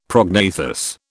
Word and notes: prognathous: British English

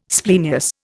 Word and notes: splenius: Indian

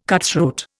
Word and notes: cutthroat: Indian

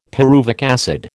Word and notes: pyruvic acid: American English

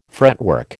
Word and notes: fretwork: American English

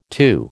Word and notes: two: American English

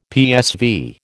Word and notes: PSV: American English

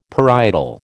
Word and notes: parietal: American English

